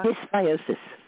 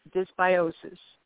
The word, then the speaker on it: dysbiosis: How do you say it? Diabosis? The flora. – Dysbiosis. – Dysbiosis. Say it one more time.